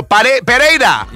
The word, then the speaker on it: Pereira: No, it looks like Perrier. – There we go. Pereira.